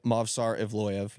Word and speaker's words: Movsar Evloev: Yep, Movsar Evloev.